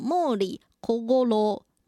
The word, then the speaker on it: 毛利小五郎: 那日文呢念作莫里科戈罗，莫里科戈罗。